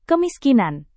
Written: kemiskinan

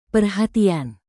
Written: perhatian

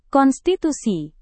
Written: konstitusi

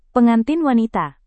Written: pengantin wanita

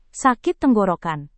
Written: sakit tenggorokan

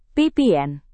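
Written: pr

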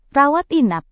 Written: rawat inap

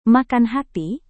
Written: makan hati

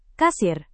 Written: kasir

